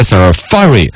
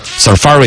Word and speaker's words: Safari: 他还专门搜了一个这个英语字典那个出来实际的发音的 Safari 应该是这样子读，嘎。 Safari 应该是重音在第二个，好像我之前是有印象的 Safari 浏览器，苹果的浏览器 Safari。英式和美式的发音呢，稍微长一点，短一点，英式的要长一点，Safari 啊，Safari，